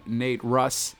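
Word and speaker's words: Nate Ruess: My Shot, which features Busta Rhymes, Joel Ortiz, and Nate Russ. Am I saying that right? Yes.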